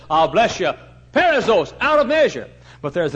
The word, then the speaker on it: perissos: P-E-R, we'd spell it P-E-R-I-S-S-O-S, perissos.